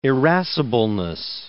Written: irascibleness

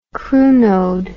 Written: crunode

